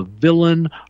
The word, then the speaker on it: villein: It's spelled villein because it's European and French. V-I-L-L-E-I-N. We would pronounce it villain.